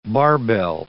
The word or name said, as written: barbell